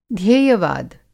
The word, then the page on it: ध्येयवाद: Hindi